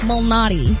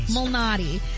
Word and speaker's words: Malnati: In golf, Peter Illuminati? – That's not it. That's not it. And I was saying it right. It's just now I'm not saying it right. Peter Malnati. – Like the pizza place.